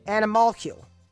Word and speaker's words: animalcule: Wait, A-N-I-M-A-L. – A-L as an animal. – Uh-huh. Olicule? – C-U-L-E. – C-U-L-E. – It's pronounced... – Animalcule. – Animalcule. Animalcule.